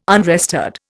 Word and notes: unrestored: Indian

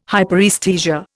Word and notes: hyperaesthesia: Indian

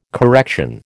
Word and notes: correction: American English

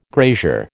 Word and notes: grazier: American English

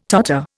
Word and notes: totter: Indian

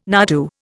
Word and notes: nardoo: Indian

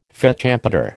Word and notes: fête champêtre: American English

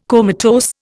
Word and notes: comatose: Indian

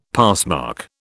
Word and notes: passmark: British English

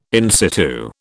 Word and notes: in situ: British English

